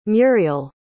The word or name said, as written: murial